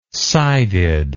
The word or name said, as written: sided